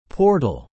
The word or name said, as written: portal